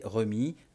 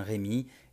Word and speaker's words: Remi: Alors, juste une petite précision justement, Rémi s'écrit sans accent. Il faudrait prononcer remis, comme je l'ai entendu pendant mes, différentes visites de Reims, mais euh, je trouve ça personnellement plus joli et plus euh, compréhensible de lui donner la prononciation actuelle, donc dans ce podcast, je dirais bien Rémi et non Remi, comme euh, je devrais le, le faire.